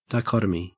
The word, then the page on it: dichotomy: Australian English